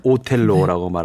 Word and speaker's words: 오텔로: 영어니까 오셀로인데 이탈리아식 발음이 오텔로래요. – 오텔로. – 그래서 베르디 오페라는 오텔로라고 네.